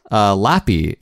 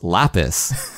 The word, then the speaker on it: Lapis: Lappy. It's not Lappy, it's Lapis.